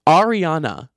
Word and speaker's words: Ariana: If you guys, I don't know why I say her name Arna. I say it like A-R-N-A. Ariana. I need to fully pronounce that.